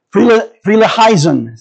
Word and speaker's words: Frelinghuysen: His name is Theodorus Frelinghuysen.